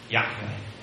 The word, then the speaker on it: Yahweh: However, in verse 15, God switches to the third person singular, which we think is pronounced like this, Yahweh, Yahweh, which means He is.